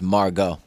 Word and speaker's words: Margot: But um, Is it Margot or Margot? I think it's Margot. Okay, there's the T is silent.